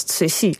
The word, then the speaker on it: Cixi: Tenminste, je spelt het als C-I-X-I, dat is lastig. Dat spreek je uit als C-C-I. Maar er stond in het boek, stond C-C-I.